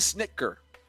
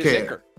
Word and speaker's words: Snitker: Brian Snitker. – Snit, pronunciando latino, Snitker. – Sí, Snitker. Como el chocolate, como Snicker, pero con Snitker.